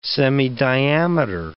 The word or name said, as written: semidiameter